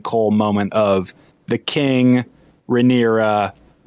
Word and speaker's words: Rhaenyra: The messenger who takes, Or I'm sorry, the bard who's performing for, what is her name again? Rhaegera? I always say it wrong. – Rhaenyra.